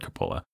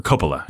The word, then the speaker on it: Coppola: And then probably next on the list was Francis Ford Coppola. Or Coppola, I should say. I always want to mispronounce his name.